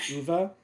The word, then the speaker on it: Uwe: let's give the Justice League to Uwe Boll. – Yeah, exactly. Actually, pronounced Uwe.